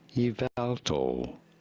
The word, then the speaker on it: Yveltal: Yveltal. You do want to stress on the second syllable. – Yveltal. – On the vowel syllable.